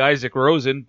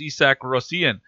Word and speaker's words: Isak Rosén: And if he was from, you know, rural Minnesota, he'd be Isaac Rosen, but he's from Sweden, and it's over there it's pronounced Isak Rosén.